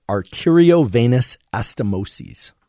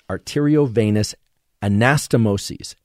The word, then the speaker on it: arteriovenous anastomoses: AVAs are arteriovenous anastomoses.